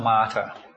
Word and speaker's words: martyr: martyr martyr อ่านว่า martyr